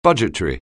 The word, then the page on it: budgetary: anglais